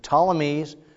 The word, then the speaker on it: Ptolemies: The Ptolemies, which is spelled with a P, out of Egypt, and the Seleucids, which are going to be more Syria.